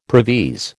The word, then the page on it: previse: American English